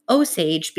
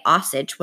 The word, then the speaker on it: Osage: Now, I had to go look up how to pronounce Osage because I was pronouncing it Osage when I read the book, but it is actually pronounced Osage.